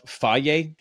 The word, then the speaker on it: Fage: Faye yogurt. It's pronounced Faye, but it's spelled Fage, Faye yogurt.